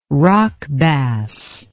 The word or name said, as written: rock bass